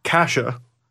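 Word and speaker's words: Kaše: Andre Kasha, who we called Case yesterday, because I don't know, that's how it's spelled, throws it on net.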